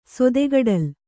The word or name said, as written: ಸೊದೆಗಡಲ್